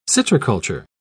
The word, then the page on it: citriculture: British English